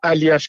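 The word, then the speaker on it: Aljaž: Là, c'est A-L-J-A-Z, mais ça se prononce vraiment Aliash, comme tu l'as dit c'est vraiment bien, par exemple.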